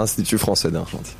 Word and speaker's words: Institut Français d'Argentine: Tenemos la primera francesa junto al de ahí lo podrá pronunciar bien, Institut François d'Argentine. ¿Lo dije bien? – Francés. – Institut Français d'Argentine.